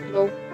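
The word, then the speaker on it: Cloud: El más joven de estos hijos de Clodomiro era San Clodoaldo, un nombre que en francés se pronuncia Clou, cuyo nombre aparece en una ciudad grande llamada saint clou cerca de Versalles.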